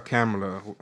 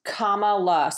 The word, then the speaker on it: Kamala: Is it Kamala or Kamala? What do you guys pronounce? – It's Kamala. So just picture the word comma with L-A afterwards. So Kamala.